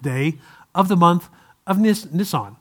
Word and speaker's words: Nisan: That month happened to be the month, and I don't know that I'll pronounce it right, but the month Nisan, N-I-S-A-N, I believe. The month Nisan. And the 10th day. Nisan, yeah, that's a car though, Nisan. So I didn't want to say it like that.